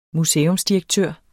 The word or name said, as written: museumsdirektør